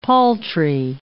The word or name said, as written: paltry